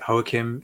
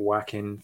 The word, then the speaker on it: Joaquin: So I pronounce it Joaquin Phoenix. I know some people pronounce it Joaquin Phoenix. – Yeah. – I'm going with the Spanish pronunciation and I'm sticking with it.